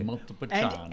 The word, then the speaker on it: Montepulciano: A C-I is pronounced ch. – Correct. – In Italian. – Montepulciano.